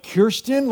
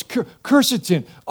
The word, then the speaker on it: quercetin: Pastor, don't you know it's quercetin, which I always thought was like Kirsten, like a person. Like, I have friends, you know, Kirsten. Like, is it like my friend Kirsten? No, it's quercetin.